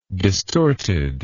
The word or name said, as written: distortedness